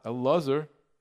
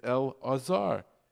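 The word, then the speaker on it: El-Azar (אלעזר): El-Azar. It's not El-Azar. It's El-Azar. You have to have the ayin in there.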